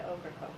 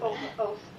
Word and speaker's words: Ocracoke: One year, with really my best friend for my freshman year in college, we went down to Okra Cove with her family. – You better spell that for me. – Okra Cove, let's see. O-C-R-A-C-O-K-E.